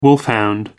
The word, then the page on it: wolfhound: American English